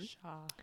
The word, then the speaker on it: Shaw: Um, Shaw. Shaw uh, the Y is silent.